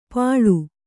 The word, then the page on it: ಪಳು: Kannada